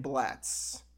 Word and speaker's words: Blatz: The 4 being Pabst, Miller, Schlitz, and Blatz, which, did I pronounce it correctly? – You did, yeah.